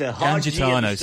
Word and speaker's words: Gangitano: Is Gangitano, sorry. And then a soft G in the middle, yes.